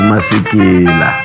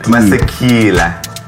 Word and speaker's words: Masekela: Okay, the proper pronunciation is Masekela. – Masekela. Nailed it. – Yeah, Masekela. And you have to smile there.